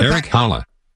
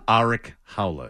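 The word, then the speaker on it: Erik Haula: So you could have Eric Halla. – There you go. Halla. – It's actually Arik. – Eric Halla. – Huh? – Arik Halla is how it's pronounced.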